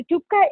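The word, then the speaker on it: thukpa: Can you talk to us about your, I don't know if I'm pronouncing it correctly, tupka, chicken noodle soup? – Tupka.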